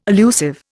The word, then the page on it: allusive: Indian